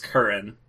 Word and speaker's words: Kern: Yeah, my name is Kern. – Yeah, but it's different. It's spelled K-E-R-N.